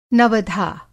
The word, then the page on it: नवधा: Hindi